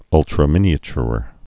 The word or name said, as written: ultraminiature